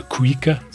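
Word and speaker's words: cuíca: I know you pronounce it a cuica, C-U-I-C-A.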